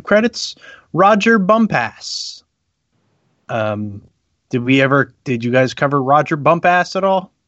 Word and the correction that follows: Bumpass: I know I'm saying his name wrong. – Yes, so Roger Bumpus is the voice of Squidward from SpongeBob SquarePants.